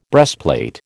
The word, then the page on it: breastplate: American English